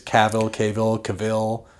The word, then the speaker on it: Cavill: It's Cavill.